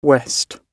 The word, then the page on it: west: British English